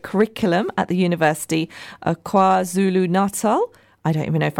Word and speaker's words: KwaZulu-Natal: KwaZulu-Natal.